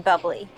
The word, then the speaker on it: Bubly: Ooh, Blackberry Bublé, my favorite. – You mean Bubly? – No, I mean Bublé. – You're Michael Bublé, that's a Bubly.